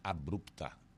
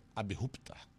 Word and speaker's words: abrupta: A pronúncia correta não é abrupta, é abrupta. Abrupta.